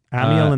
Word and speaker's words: Amyl: How do you say that? What's that name? – Amyl. So A-M-Y-L. – Okay. – Amyl and the Sniffers. – Amiel and the Sniffers.